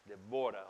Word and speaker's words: Deborah: Actually, the word is pronounced like Deborah. Deborah, almost like kabod.